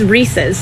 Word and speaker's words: Reese's: It is Reese's. It is a guy named Reese, and it is his. It's his. Reese's. And it's in the commercial. Reese's Puffs, Reese's Puffs. R-E-E-S-E-S.